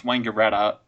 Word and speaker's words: Wangaratta: Which, for foreigners trying to pronounce Australian town names, you pronounce Wangaratta like it's a person's name. Imagine their first name is Wayne and their last name is Garatta. Wayne Garatta. There you go.